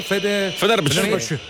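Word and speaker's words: Fenerbahçe: Sì, è proprio la playlist del, come si pronuncia, scusate, Fenerbahce.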